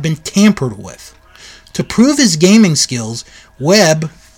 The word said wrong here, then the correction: Wiebe: I, I, let me let me double check, make sure I pronounced that right. Oh, Wiebe? Wiebe? Okay, it's Wiebe.